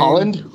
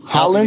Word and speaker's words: Haaland: what or how, how do you say his last name? – Haaland. – Haaland.